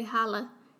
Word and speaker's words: Hollow: It's spelled like hollow, but they say holla.